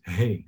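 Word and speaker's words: Hey (ה): This is how you pronounce it. Hey. Like you say, like hey, hey house, right?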